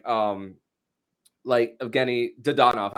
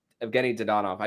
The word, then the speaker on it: Evgeny Dadonov: And I feel bad for someone like Evgeny Dadonov. I said his name incorrectly the first time. Evgeny Dadonov.